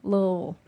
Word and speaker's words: Louisville: They make t-shirts there that say, like, L-O-O-V-U-L. That's my favorite pronunciation. Louisville.